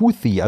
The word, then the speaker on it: Houthi: Diese Miliz heißt Houthi, also tatsächlich mit so einer Art TH wie im Englischen.